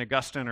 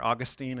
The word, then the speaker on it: Augustine: This is St. Augustine, or Augustine is how some people say his name, a really famous foundational thinker and theologian.